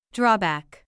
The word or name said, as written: drawback